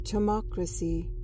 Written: chumocracy

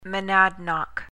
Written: monadnock

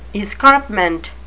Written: escarpment

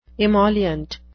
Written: emollient

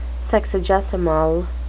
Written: sexagesimal

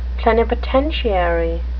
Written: plenipotentiary

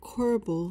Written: corbel

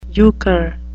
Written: euchre

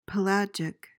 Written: pelagic

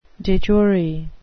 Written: de jure

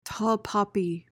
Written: tall poppy

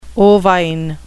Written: ovine

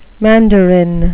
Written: mandarin

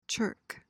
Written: chirk

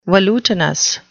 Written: velutinous

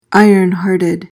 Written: iron-hearted

